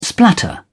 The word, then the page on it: splatter: British English